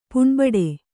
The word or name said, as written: ಪುಣ್ಬಡು